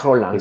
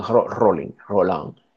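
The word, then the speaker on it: Rollin: Jean Roland, se escribe Rolling, Jean Roland,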